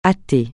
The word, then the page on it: athée: francais